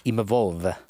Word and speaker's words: Imavov: Nasrin Imavov. – Imavov.